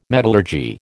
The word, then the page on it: metallurgy: American English